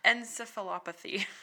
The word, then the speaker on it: encephalopathy: So, bovine spongiform I pronounced this earlier and I was like, I got it. Encephalopathy. – Yes.